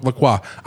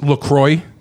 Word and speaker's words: LaCroix: So OIX would be pronounced wa in French. So it's therefore true. I go with LaCroix. I know it's LaCroix. – One thing we could agree on is the X is silent no matter what.